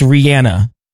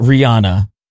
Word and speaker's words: Rihanna: Were you a part of, like, where did you fall when everybody in radio was like, it's Rihanna and not Rihanna? – I always knew it was Rihanna.